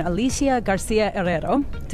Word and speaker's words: Alicia Garcia Herrero: I am honored to bring in Alicia Garcia Herrero.